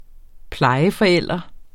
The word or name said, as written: plejeforælder